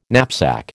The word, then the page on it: knapsack: American English